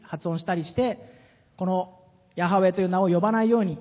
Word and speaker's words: ヤハウェ: 英語では YHWH、私たちのカタカナの母音が一緒の発音に直すとヤハウェと、まあ、今呼ぶことが許されていると思うんですが、まあ、そのヤハウェニシというふうに聖書にオリジナルテキストには書かれているんです。